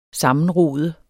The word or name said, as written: sammenrod